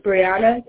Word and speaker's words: Bryanna: My name is Brianna Breitner. – B-R-I-A-N-N-A. And your last name, Brianna? – My first name is spelled B-R-Y-A-N-N-A.